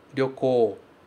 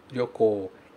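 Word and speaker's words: Ryoko (旅行): Esse R sempre tem som de rarirurero. Ryoko, Ryoko. E outra coisa importante também de falar é esse OU que vem no final. Se escreve OU, mas se fala O. Ryoko, Ryoko, ok?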